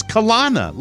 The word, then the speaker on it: Kelowna: You had given me C-O-L-O-N-A, I would have said Kelowna. Would you put the two N's after the O? It's Kelowna, like a colonoscopy. – Well, we stand in disagreement then, because I saw C-O-L-O-N-N-A.